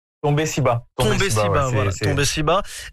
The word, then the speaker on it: Tombé Siba: Par hasard, et puis en 2020 on commence à avoir une sorte de révélation avec le single, alors Tombé Sida, est-ce que je le prononce bien? – Tombé Siba, et qui arrive en 2020 et qui cumule plusieurs millions de vues sur les plateformes de stream.